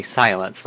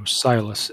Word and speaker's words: Silas: All right, so the name of the contact just comes across as Silas. – But not actually silence, like dot, dot, dot. – No, Silas. S-I-L-A-S. – Wait, can you spell that for me again? – S-I-L-A-S.